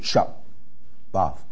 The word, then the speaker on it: shabbat: 是 shabbat 啊，这个 shabbat 啊，那重音要放在前面啊，因为前面它是短母音 open syllable shabbat shabbat shabbat 啊，重音放在前面 shabbat。